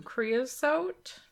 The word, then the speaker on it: creosote: It can contribute to creosote buildup. I don't have a fireplace. Did I say that right? – Yeah, you did. You said that right.